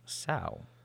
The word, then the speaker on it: sow: The verb sow is pronounced completely different from the noun sow, which means a female pig. But when you sow flower seeds, it rhymes with go. When you admire an enormous muddy sow in a pig pen, it rhymes with cow.